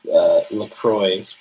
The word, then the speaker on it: LaCroix: Well, so it's like the island is called St. Croix, right? C-R-O-I-X, right? Which is French. That's not the French pronunciation. And even on LaCroix's website, right? They like say it's LaCroix.